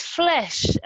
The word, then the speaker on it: Flesch: It's flesh, F-L-E-S-C-H. – Oh, and yes. – Kincaid is K-I-N-C-A-I-D.